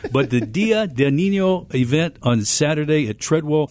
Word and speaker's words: Día del Niño: Día del Niño. – Día del Niño.